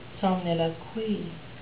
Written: somniloquy

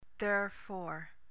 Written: therefor

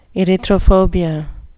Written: erythrophobia

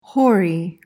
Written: hoary

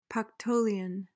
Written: pactolian

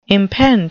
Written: impend

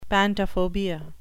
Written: pantophobia

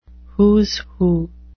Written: who's who

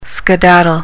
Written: skedaddle

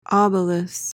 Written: obelus

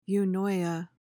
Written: eunoia

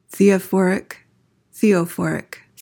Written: theophoric

